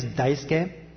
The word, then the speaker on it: Daisuke: By the way, my name is Daisuke, and if you have look at the bulletin, you have D-A-I-S-U-K-E, and people will call me Daisuke or Daisaku or whatever that might be. But the U is silent, and everybody here has been pronouncing it perfectly. It's actually should be spelled something like D-I-C-E-K. So it's dice and K.